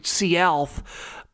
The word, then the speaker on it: Sealth: It's actually Sealth, but apparently people back then couldn't say Sealth, but they said Seattle, so that's how it became Seattle.